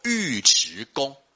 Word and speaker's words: 尉迟: 好、哦，然后呢，他还有另外一个搭档啊、哦，也是呢开国元勋，叫做尉迟恭。好、哦，那很多人都会把那个字念作尉了哈、哦，他是一个复姓。复姓尉迟，好，那这两位呢，哦，都是呢，李渊，好、哦、的这个大将。